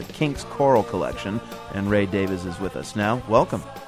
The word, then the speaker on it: Davies: It was written by Ray Davies, and yes, that is the way he pronounces his name.